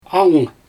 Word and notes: angyng: Xyzyl

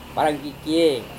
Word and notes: warangkikiei: Serewen

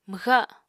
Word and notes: mwxa7: Apatani

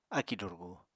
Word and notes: akì durgu: apatani

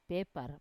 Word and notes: pepar: wamesa

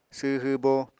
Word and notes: sww hwbo: apatani

